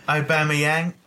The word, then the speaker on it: Aubameyang: I now know how to pronounce his name. Aubameyang, obviously.